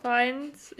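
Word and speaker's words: Fiennes: Ähm, der Voldemort wird nämlich von Ralf Finnes, Finnes, ich ja. weiß auch, wie es heute nicht wie ausgesprochen wird. – Ähm, Neffen gespielt und zwar Hero. Äh, Harden Scott. Feins, ja, ja, von Harden Scott aus Afterlove, ne?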